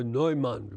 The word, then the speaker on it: Neumann: There was Teresa Neumann, or Neumann would be the correct pronunciation.